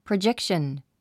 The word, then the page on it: projection: prədʒékʃən; プロジェクション